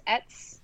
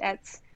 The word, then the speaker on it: ETCE: Name's Etz, E-T-C-E, like et cetera, but just Etz.